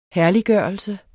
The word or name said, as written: herliggørelse